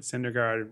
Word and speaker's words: Syndergaard: Oh, Who, Cuddyer retired reminds me that Syndergaard rhymes with wild card.